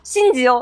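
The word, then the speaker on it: 新二: 然后这个地球人他叫神永星二，他的名字叫卡米纳嘎信吉，信吉哦，哦，也就是跟那个真四的发音是一样的。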